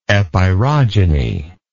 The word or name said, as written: epirogeny